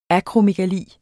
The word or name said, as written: akromegali